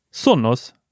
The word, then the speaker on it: Sonos: Hur uttalar du det? – Sonos. Var det inte lite två ända? Sonos? Nej, nej, nej, nej, nej. inga Sonos här. Sonos. Sonos. Inte Sonos. – Sonos.